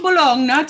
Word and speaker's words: Bologna: We're going to Bologna tomorrow.